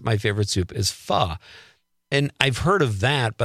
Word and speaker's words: pho: Is it pho? – It's pho. – Pho. Okay.